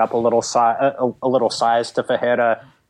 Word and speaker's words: Ferreira: Do you pronounce none of the R's as R's or one of them as R? – You pronounce the second batch as R's. – So Ferreira. – Yeah, Ferreira.